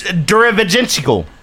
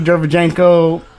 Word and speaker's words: Derevyanchenko: It's Derevyanchenko. – No, yeah, that's not how you say it. – Sure. It's Derevyanchenko. – It's Derevyanchenko. – Derevyanchenko. – It's like Lomachenko.